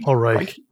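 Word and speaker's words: Paul Reiche: Like, was it Paul Ritchie? – Paul Reich. – Reich. Okay. Yeah.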